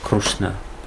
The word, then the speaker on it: Кришна: То же самое слово Кришна, оно по-разному произносится. В Арисе они говорят Крушна, Нарусимха.